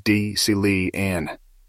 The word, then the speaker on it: Decelean: The term Decelean is generally pronounced as Decelean.